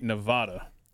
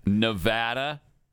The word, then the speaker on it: Nevada: You love it when people say Nevada. – I hate. Oh, yeah, I do. It's Nevada. Nevada is the way you pronounce that.